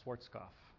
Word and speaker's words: Schwarzkopf: This is Brother Swasku, or better known as Schwarzkopf. This would be a better pronunciation.